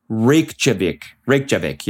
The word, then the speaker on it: Reykjavik: How do you actually pronounce that? Reykjavik. Yeah, it's actually way easier than I was making it out to be.